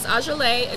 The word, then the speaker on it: Ajeleah: My Instagram is Ajalay.